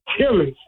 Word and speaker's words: Tillis: Tillis.